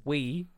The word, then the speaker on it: Wii: It's pronounced Wii and it's spelled W-I-I.